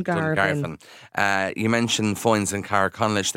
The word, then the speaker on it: Dungarvan: Other way can you pronounce that? – Dungarvan, yes. – Oh, really? – No. You're saying it right.